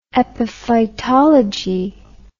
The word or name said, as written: epiphytology